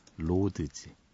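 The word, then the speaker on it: Roads: 트리팝이라는 장르로 분류됐던 포트쉐드의 로즈, 로즈 장미란 뜻이 아니고요. 예, 로드에 S가 붙어서 뭐라고 발음해야 되죠? 예, 로즈, 네, 로드즈.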